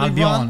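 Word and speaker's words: Albon: He said, F1 for Anto, who do Red Bull need to do with Alex Albin? Are Red Bull poorly Albion. – Run? – It's not Albin, Albion.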